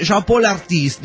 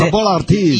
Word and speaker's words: Chapeau l'artiste: Dice, en el otro mail yo les decía Chapeau l'artiste, dice, que es una expresión en francés que se pronuncia fonéticamente Chapeau l'artiste, dice. – Chapeau l'artiste.